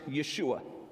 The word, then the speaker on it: Yeshua: What's cool, the name Jesus in Hebrew and Aramaic is pronounced Yeshua.